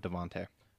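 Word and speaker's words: DeVonta Smith: I'm going to go with Devontae.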